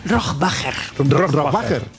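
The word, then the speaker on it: Rohrwacher: Bueno, ¿cómo se pronuncia Alba Rohrbacher Cochet? – Rohrbacher.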